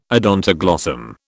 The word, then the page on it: odontoglossum: British English